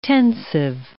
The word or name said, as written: tensive